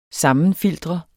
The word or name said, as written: sammenfiltre